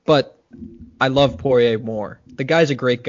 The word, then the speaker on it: Poirier: Is that how you pronounce it? – Poirier. – Close enough. Poirier. French enough.